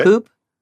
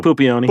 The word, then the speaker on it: Puppione: P-U-P-P-I-O-N-E. – Pupioni. – Yeah. – Pupioni? – Yeah. – Like a poop? – No. No. Like Pupioni.